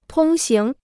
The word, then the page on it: 通行: tōng xíng